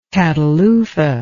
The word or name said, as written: catalufa